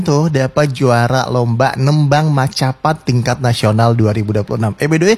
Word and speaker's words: Macapat: Mocopat sih kak bacanya. – Oh bacanya mocopat ya. Oh agak ke Jawa-Jawa gitu.